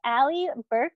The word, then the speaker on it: Allie Burk: We have Allie Burk on today's episode.